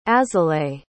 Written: azalaïs

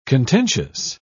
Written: contentious

